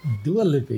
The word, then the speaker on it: Dua Lipa: And Billboard recently published a report outlining a decrease in radio play for Dua Lipa, if I'm saying it right, and DaBaby's. – Dua Lipa, yes, my daughter's favorite song, Levitating.